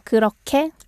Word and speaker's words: 그렇게: But it would be great that you can make the sound like 그렇게. – 그렇게. – Yes. – It's not spelled that way. It's spelled 게, but 게. – Yeah. – 그렇게. – Yeah. But other than that, that's good overall.